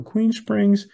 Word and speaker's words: Aquene Springs: Where do you see Aquin Springs in the next 5 years? I hope I said that right. – You did. Thank you. Aquin Springs.